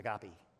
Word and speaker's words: agape: I always pronounce it agape, he pronounced it agape.